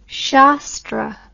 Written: sastra

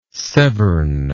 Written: Severn

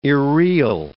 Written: irreal

